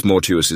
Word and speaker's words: mortuus: For thus mortuus is pronounced like fatuus, arduus, conspicuous, and similar words, which are not perfect participles but adjectives, and are declined without regard to tense.